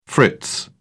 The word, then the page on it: fritz: British English